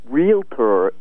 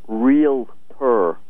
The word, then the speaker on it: realtor: Realtor is realtor with two syllables. It's not realtor with three.